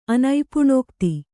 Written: ಅನೈಪುಣೋಕ್ತಿ

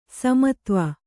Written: ಸೋಮತ್ವ

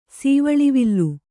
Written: ಸೀವಳಿವಿಲ್ಲು

